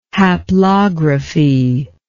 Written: haplography

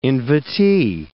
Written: invitee

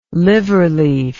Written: liverleaf

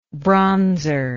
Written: bronzer

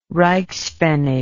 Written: reichspfennig

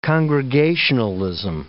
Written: congregationalism

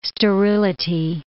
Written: sterility

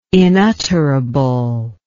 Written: inutterable

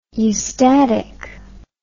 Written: eustatically

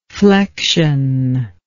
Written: flectionless

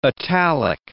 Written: italic

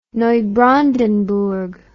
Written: Neubrandenburg

